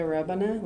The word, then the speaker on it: Rebane: My name is Kaya Rebane. Last name is R-E-B-A-N-E.